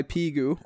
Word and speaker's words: Pigu: Pigu's Mental Farts is the title of the pod, and it's published by Pigu, or Pigu, because it's spelt like the mathematical word pi.